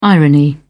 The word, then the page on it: irony: British English